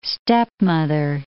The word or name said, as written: stepmother